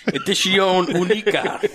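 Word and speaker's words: Edición Única: It's U-N. – E-D-I-C-I-O-N-U-N-I-C-A. – Huh. – It's Spanish, Rick. – Yeah. – Edición Unica.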